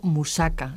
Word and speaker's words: musaka: También se utiliza la carne picada en el famoso, que por cierto a mí me encanta, en el famoso musaka, ¿no es así? Y no sé si lo he pronunciado bien. – Sí, sí, muy bien.